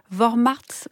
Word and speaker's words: Vormärz: Vous avez donné le terme allemand, Isabelle Garraud, c'est Vormärz.